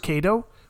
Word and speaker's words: Cato: Both Catos are always pronounced with a hard C, as in Cato, yet Cicero, or Caesar himself, is pronounced phonetically as Cicero, or Caesar.